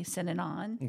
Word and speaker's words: Synanon: from a group called Synanon, like synonym only Synanon.